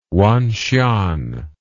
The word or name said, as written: wanxian